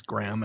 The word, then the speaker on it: Graham: One of them is, why doesn't Graham, the name Graham, G-R-A-H-A-M, rhyme with Abraham? – Well, my middle name is Graham, and is it really? – Actually, yeah. Well, I pronounce it as Graham.